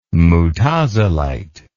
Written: mutazilite

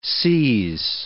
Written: seize

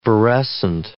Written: virescent

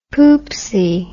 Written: poopsie

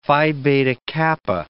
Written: Phi Beta Kappa

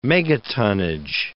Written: megatonnage

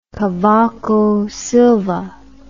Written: Cavaco Silva

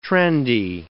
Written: trendy

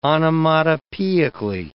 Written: onomatopoeically

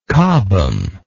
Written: cobham

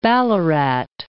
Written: Ballarat

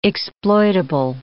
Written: exploitable

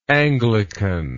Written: anglicanly

